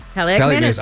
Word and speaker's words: Kaliakmanis: Dude, not only that, but how do you say it, Kaliakmanis? – Kaliakmanis.